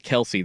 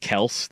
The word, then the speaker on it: Kelce: Like I understand like the start of this, but I do think that Kelce kind of like date Beyonce, bro. – I don't know if you heard his dad. He actually said it's not Kelce. Their family name is pronounced Kelce.